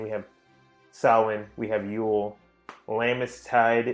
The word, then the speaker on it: Samhain: We debated on how to pronounce Samhain or Samhain, and it's pronounced Samhain, I was informed.